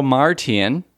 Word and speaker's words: Omartian: Michael Omartian, now we're pronouncing it right for the first time here on our podcast, played synthesizers on the album, not the song, and it has that sort of smooth quality.